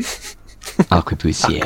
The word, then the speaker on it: arquebusier: Arquebusier.